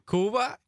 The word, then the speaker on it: Cuba: Because everyone says Cuba, but there is Cuba, right? – Cuba.